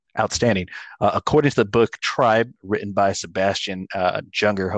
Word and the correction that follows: Junger: I think it's actually Junger. – Thank you. – It's a lot of those languages, the J and the Y.